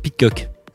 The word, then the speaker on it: Pidcock: Vous avez du Van der Hart, Lars et vous avez le champion olympique de VTT, Tom Pidoc. De quoi Pidcock. Excuse-moi, les prononciations, ça va arriver.